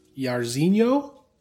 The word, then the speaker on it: Jairzinho: heavyweight fight between Alistair Overeem and Jairzinho.